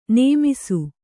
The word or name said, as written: ನೆಮ್ಮಿಸು